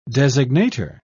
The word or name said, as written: designator